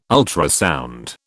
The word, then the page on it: ultrasound: British English